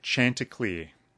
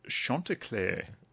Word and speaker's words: Chanticleer: I pronounced it Chanticleer. It's a type of ornamental pear. I said, those Chanticleers are beautiful that you've got over there. And the fellow said... Oh, no, it's Chanticleer.